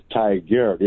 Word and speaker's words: Tigard: it almost sounds like tiger, but it's Tigard. It's like T-I-G-A-R-D.